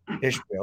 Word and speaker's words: Ishbia: Ishiba. Am I pronouncing that right? – I think so. It's either Ishiba or Ishbia. – Ishbia.